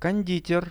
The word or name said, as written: konditer